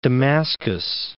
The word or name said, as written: Damascus